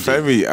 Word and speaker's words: Femi: So, my name is Femi, and like, I just wanted the name on Instagram.